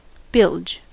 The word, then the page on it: bilge: American English